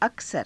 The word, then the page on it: aksara: Hindi